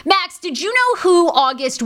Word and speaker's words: August: I always want to say it, Augusta, but it's August.